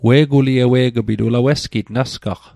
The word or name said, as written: ewe'g'pit